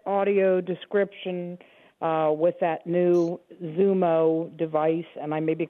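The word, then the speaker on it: XUMO: It's spelled X-U-M-O, a Zumo, so you pronounce it as if it's a Z.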